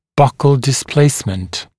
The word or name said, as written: buccal displacement